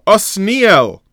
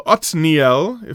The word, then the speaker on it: Otniel: He has a daughter who marries Osniel. Osniel is Kalev's half-brother, who ends up being Kalev's son-in-law. And this is important, and please keep this in mind. Otniel, if pronounced in the other manner, who is Kalev's son-in-law, is explained as being the first shofet, the first national leader.